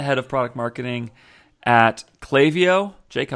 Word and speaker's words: Klaviyo: But Klaviyo, we had to make phonetic pronunciation t-shirts to help people know it's Klaviyo.